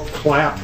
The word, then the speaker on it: Clapp: Bill Clapp, that's spelled C-L-A-P-P.